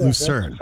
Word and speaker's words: Lucerne: Is it Lucerne, Switzerland? Am I saying that correctly? – Lucerne, I think. – Yes, Lucerne.